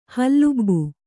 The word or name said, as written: ಹಲುಬು